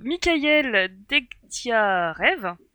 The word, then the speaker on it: Mikhaïl Degtyarev: Donc, Mikhaïl Degtyarev, ça se dit comme ça, voilà.